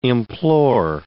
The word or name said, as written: implore